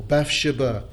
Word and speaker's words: Bathsheba: A psalm of David, when Nathan the prophet came unto him after he had gone in to Bathsheba, or Bathsheba, as is often pronounced.